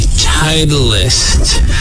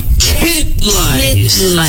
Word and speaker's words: Titleist: The Gulf Company Titleist. They're doing... – It's... Titleist. – Oh, sorry. – Said it wrong. Titleist. – Yes. Titleist. No, still saying it wrong. Titleist. – Titleist. Yeah.